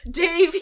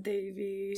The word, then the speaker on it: Devi: Davy. – It's pronounced Davy.